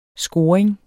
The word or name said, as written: scoring